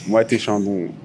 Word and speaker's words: Moët et Chandon: Moët et Chandon.